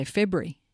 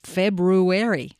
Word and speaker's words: February: Yeah, a catchy ad, and the way that February is pronounced in that ad is something that caught our ears, because they do pronounce the word the way it's spelt, which a lot of people these days don't. Most people say February instead of February. You've got to slow down and say it with the full February.